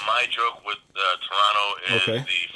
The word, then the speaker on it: Toronto: But it's so I learned that it's Toronto. You don't pronounce the T.